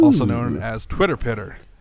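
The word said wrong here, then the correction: twitterpated: Twitter pated. – Twitter pated. I said it wrong.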